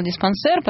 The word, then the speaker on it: диспансер: Да, вот все, пишет, что это слово французского происхождения, ударение на последнее слово, говорим по-русски. Да, разобрались просто. Я так и сказала диспансер, потом мне Нелли поправила, я неправильно прочитала его сообщение.